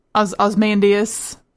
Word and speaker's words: Ozymandias: So it's strange to me that we can assume that Ozymandias, that's how you say it, right? Uh-huh. I said it wrong before. I keep wanting to call him Ozymandias. I don't know why.